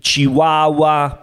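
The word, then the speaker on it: Chihuahua: Порода собака неизвестна, он бастардо, он не 100% аски, чихуахуа, понимаешь, бастардо. – Понимаю, ты хотел сказать чихуахуа? – Чихуахуа, чихуахуа по-итальянски, чихуахуа.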